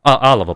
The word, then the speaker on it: Алава: И за это Наварра и Алава при франкизме, да, мы всегда говорим про что франкизм это унифицирующий, но Алава, Алова, прошу прощения, там ударение на первую букву,